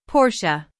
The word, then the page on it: Porsche: /ˈpɔːr.ʃə/; American English